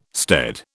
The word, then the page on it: stead: British English